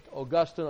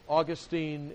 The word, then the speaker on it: Augustine: I was listening to a lecture from Gordon Clark this week, and he said, St. Augustine is in Florida, St. Augustine is in heaven. So now you know how to pronounce all that.